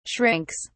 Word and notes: shrinks: British English

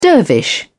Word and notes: dervish: British English

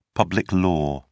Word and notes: public law: British English

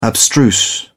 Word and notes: abstruse: British English

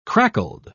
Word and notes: crackled: British English

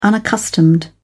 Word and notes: unaccustomed: British English

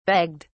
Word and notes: begged: British English

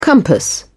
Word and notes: compass: British English

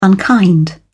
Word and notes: unkind: British English

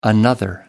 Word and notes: another: American English

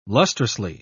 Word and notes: lustrously: British English

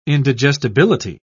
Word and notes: indigestibility: British English

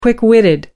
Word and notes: quick-witted: American English

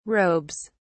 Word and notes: robes: British English

